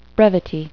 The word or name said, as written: brevity